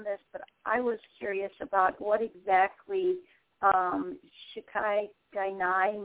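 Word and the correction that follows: shikata ga nai: means, which I'm pronouncing wrong. Let's see. – Shikati Gainai.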